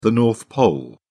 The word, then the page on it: North Pole: British English